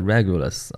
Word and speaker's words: Regulus: 太阳会从它这个星星上面经过，啊，既然这颗星那么的耀眼，那么就赋予了它一个王者之星的这么一个概念啊，所以它的啊拉丁名字叫做 Regulus 啊，这个当然这是英文发音了啊，Regulus 它的意思就是小王啊，这反正就是王者之星嘛啊，在天上还是四大天王之首啊，这事儿咱就先不说它了啊。